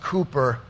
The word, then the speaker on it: Cowper: William Cowper, pronounced Cooper, in the 1800s, 1700s possibly, out of England.